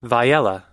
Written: viyella

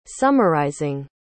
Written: summarizing

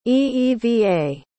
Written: eeva

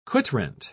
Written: quitrent

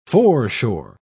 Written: foreshore

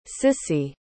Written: cissy

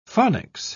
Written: phonics

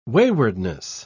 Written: waywardness

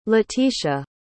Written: letitia

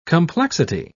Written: complexity

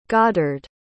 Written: goddard

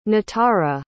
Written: nitara

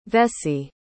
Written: vesey

